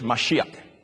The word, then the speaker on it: Mashiach: Do you know today in Israel, they are waiting for the Mashiach to appear? They call him Messiah, or they pronounce it in the Hebrew language, Mashiach.